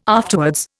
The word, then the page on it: afterwards: Indian